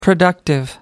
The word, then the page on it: productive: American English